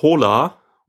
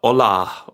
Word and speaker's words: Hola: Aber ähm, was ich äh, vorstellen wollte, ist das äh, Plugin für Chrome äh, namens Hola oder Ola oder H-O-L-A, wie, wie auch immer du das aussprechen möchtest, kann ich vielleicht äh, dir kurz mal zeigen.